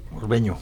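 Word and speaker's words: Morbegno: Camilo Magoni nació en Morbeno, no sé si lo digo bien. – Morbeño. – Morbeño. Morbeño, Italia. O sea, la N se pronuncia como Ñ la en italiano.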